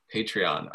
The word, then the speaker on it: Patreon: Join, you know, if you're out there, is it Patreon? Is that how you say it? – Patreon.